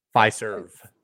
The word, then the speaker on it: Fiserv: How do you say it? Pfizer? – Fiserv Forum.